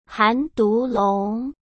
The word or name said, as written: Han Dulong